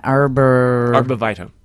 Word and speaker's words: Arborvitae: Arborvitae. – Okay. This is 630. I have a globe. – Arborvitae.